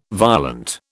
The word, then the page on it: violent: British English